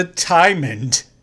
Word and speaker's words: Timond: The thing that they're searching for is called the Timond. Even a character even says, oh, it rhymes with diamond.